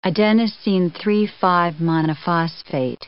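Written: adenosine 3',5'-monophosphate

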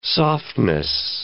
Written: softness

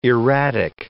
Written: erratic

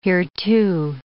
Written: hereto

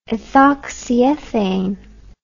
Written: ethoxyethane

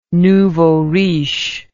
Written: nouveau riche

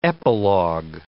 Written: epilog

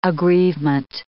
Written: aggrievement

